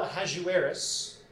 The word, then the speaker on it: Ahasuerus: But then during Xerxes I, as we know him in the Bible, Ahasuerus. That's how I pronounce it.